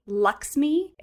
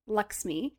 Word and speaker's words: LXMI: It's by the company Luxmi, but it's spelled LXMI. Luxmi.